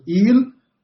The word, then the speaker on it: El: ele sucumbe e se entrega ao Deus cananeu. Quem era o Deus cananeu? O Deus El, ou Il. Em árabe nós pronunciamos Il, e no aramaico também il, aramaico, a língua de uhum.